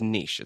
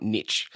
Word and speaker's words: niche: Yeah, I mean, like I said, I think you are proudly niche, and that's how you say it, Charles. It's niche. It's not niche. I hate hearing niche.